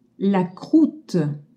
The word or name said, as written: croute